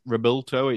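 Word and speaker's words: Revuelto: And I had a good look at the Rebulto. It's supposed to be pronounced in Spanish, which is the new V12.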